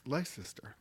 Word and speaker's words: Leicester: How do you pronounce that? Leicester? Leicester? – Leicester. – Leicester, England.